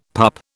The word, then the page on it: pup: British English